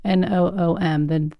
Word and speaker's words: Noom: Right now, Gnome. I probably say it wrong. Noom.